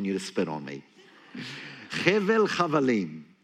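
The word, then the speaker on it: Hevel Chavalim (הבל הבלים): The other phrase he uses, he calls it Hevel Chavalim. That's how you say it in Hebrew.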